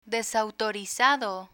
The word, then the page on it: desautorizado: Spanish